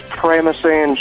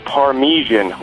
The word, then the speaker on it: Parmesan: Maybe 4 years ago was your pronunciation of Parmesan cheese. It's actually pronounced Parmesan.